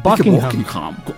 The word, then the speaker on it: Buckingham: Es Buckingham. – Eso sí. Y de hecho, el H medio que se es medio silente. Buckingham. – Más House. – O menos. – No se pronuncia mucho.